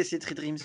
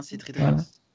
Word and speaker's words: C3Dreams: Dites-nous sur Instagram ou Twitter si vous prononcez Cedream ou C3 Dreams. – Ouais, faudrait voter C3 Dreams parce que c'est bien C3 Dreams.